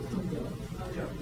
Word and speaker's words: Hangzhou: Is it properly pronounced Hangzhou? – Hangzhou.